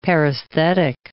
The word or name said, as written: paresthetic